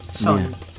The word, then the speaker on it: Sean: John. El que se escribe Sean, pero se pronuncia John. – Exactamente. – A diferencia de John. – S-E-A-N.